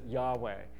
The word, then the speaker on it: Yahweh: the tetragrammaton, the YHWH, some people pronounce it Yahweh.